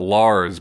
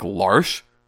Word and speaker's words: Lars: Everybody pronounces it Lars, but where that character is from, that spelling of that name would be pronounced like Larsh.